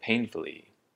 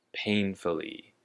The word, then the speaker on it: painfully: And next we have the word painfully. Painfully. And the emphasis is on the first syllable. Pain. Painfully.